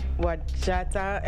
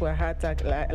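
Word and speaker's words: Wajatta: And the name of the group comes from the combination of their songs, and it's pronounced Wahata, like the J is pronounced as an H.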